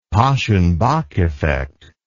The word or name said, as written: paschen back effect